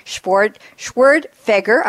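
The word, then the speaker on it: Schwerdtfeger: So first up is Patrick Schwertfeger.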